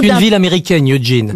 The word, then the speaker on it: Eugene: E-U-G-E-N-E. Comme Eugène. – Mais pourquoi on prononce Eugène alors? – Ben parce que, effectivement, ça se passe pas chez nous en France.